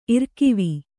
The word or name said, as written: ಇರ್ಕಿವಿ